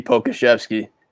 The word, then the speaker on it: Pokuševski: For the Charlotte Hornets, we're going to go with a surprise pick here, and we're going to go with Alexei Pokuševski. – Oh, and you said it right, too.